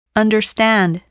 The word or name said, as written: understand